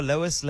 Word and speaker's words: Lois: I thought it was a silent E. – Yes, I know. But like on a something like that, your Lois Lane.